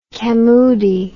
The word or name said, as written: camoodi